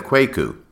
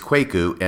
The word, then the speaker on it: Kwaku: If you go back and listen to that episode, you will hear that throughout I pronounced his name as Kwaku instead of Kwaku. Since the time I recorded that episode, I have been advised by a number of individuals that the correct pronunciation is Kwaku and not Kwaku.